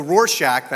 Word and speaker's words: Rorschach: It's a very famous, the Rorschach, that's how you pronounce it, the Rorschach test.